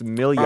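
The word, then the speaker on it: Milian: Upon his retirement, there was a series of other adjudicators with Judge Marilyn Million? Oh, pronounced Million.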